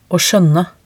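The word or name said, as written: å skjønne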